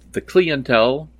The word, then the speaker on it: The Clientele: One of my favorite bands, The Clientele, which I now understand is pronounced The Clientele, has a new record out this year.